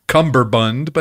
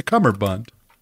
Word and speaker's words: cummerbund: It's not cummerbund with a B, though it's more fun to say cummerbund, but it's a cummerbund.